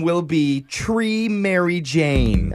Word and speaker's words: Chree: So when Tennille got pregnant, she decided to name her daughter Tree. And spelled it C-H-R-E-E. So Tree.